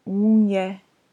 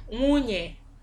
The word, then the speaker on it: Munye: Wife is, and correct me if this pronunciation is wrong. – Mm-hmm. – Wife is Munye. – Yeah, Munye. – Munye. – Mm-hmm. Munye. – Munye. – Mwe, u, nye, e. Munye.